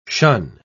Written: shun

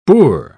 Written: boor

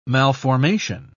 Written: malformation

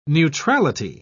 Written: neutrality

